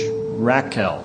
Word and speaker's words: rakal: The Hebrew word trading is, well, we spell it in the English, Rakel. R-A-K-A-L.